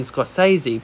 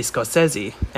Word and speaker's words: Scorsese: It's like most people pronounce Martin Scorsese as Martin Scorsese by accident, but the real way to pronounce it is actually Scorsese.